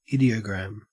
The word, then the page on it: ideogram: Australian English